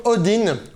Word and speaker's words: Odin: Me voici avec la Wild Audio Odin, ou Odin, hein, si vous la prononcez à la française.